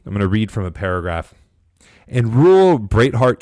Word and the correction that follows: Breathitt: County, Breathitt, I apologize if I mispronounced that, I'm not from Kentucky, there were 9,508 registered Democrats and just 1,599 registered Republicans.